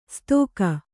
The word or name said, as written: ಸ್ತೋಕ